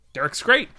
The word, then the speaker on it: Derek Scrate: Derek Scrate. – Derek Scrate. – Derek Scrate.